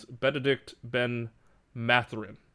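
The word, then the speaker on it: Bennedict Mathurin: It is Benedict Ben-Mathurin. – Ben-Math. – Much easier. – You did pronounce that correctly.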